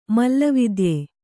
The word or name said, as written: ಮಲ್ಲವಿದ್ಯೆ